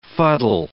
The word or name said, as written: fuddle